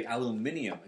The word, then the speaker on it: aluminium: And that's why the Brits say aluminium and we say aluminum.